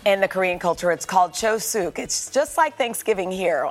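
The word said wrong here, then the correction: Chuseok (추석): Chuseok. Happy Chuseok, everybody.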